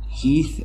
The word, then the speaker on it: Heath: East Elementary. – Heath Elementary School. That's spelled H-E-A-T-H.